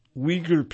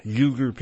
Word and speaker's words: Uyghur: In addition to their affiliation with Islamic fascist offshoots of the Muslim Brotherhood, such as Al-Qaeda and the Islamic State, the Uyghur people, or Uyghur people, sometimes it's pronounced U-I, sometimes spelled U-Y-G-H-U-R,